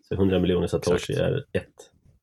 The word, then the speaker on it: satoshi: Satoshis är också populärt. – Satoshi, kanske. – Mm. Just det. Just det. Om pluralformen skulle följa de japanska grammatiska reglerna så ska det uttalas satoshi. Eller satoshisa.